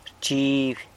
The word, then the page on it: rchiby: sanlucasquiavini